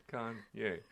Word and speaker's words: Kanye: Kane. N before Y. Kanye.